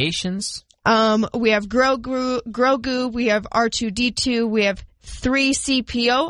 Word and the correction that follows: C-3PO: I know that one. – Nope. C-3PO. – I flipped it around.